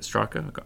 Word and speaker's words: Strache: And the leader of that party, Heinz Christian Strache, is it Strache? I'm saying it right? – Yeah.